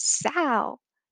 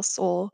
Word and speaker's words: Soul: And all of a sudden he goes, and the winner is Sal. And, you know, he knew that he fucked it up. He pronounced it wrong. That's something I would do. Sal, Soul.